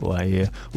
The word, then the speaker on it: Lair: Then we went over to Indonesia with a brand new release from Laia, spelt Lair in English, but pronounced Laia.